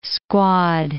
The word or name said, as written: squad